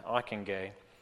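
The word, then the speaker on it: Ockenga: Under the leadership of Harold Ockingay, and that is how you say that, Ockingay, like McIntyre, a former student of Machen, neo-evangelicals also sought ecumenical expression through the founding of the National Association of Evangelicals.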